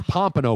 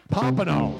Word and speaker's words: Pompano: It's Pompano Beach, Robin. – Come on now. Excuse me. – Pompano. – Where's the button on that one? – Let me give it one of those. – How do you say it again? – Pompano. – Pompano. – Named after a fish.